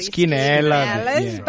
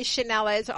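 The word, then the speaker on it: Cinellas: Well, the correct Italian pronunciation is obviously Chinellas. But here in Australia, in Adelaide, we all know Chinellas on Prospect Road.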